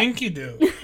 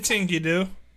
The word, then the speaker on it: Enkidu: Enkidu. No, it's Enkidu.